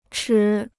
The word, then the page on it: 持: chí